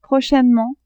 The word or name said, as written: prochainement